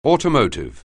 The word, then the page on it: automotive: anglais